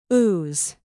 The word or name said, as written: ooze